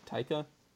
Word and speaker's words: Taika: That's how you say his name, right? Taika? – Yeah, I believe so.